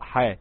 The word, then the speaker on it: حا: يعني الحاء في القرآن بتنطق حا مش حاء، حا، والراء بتنطق را، والطاء بتنطق طا، وهكذا.